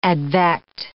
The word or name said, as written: advect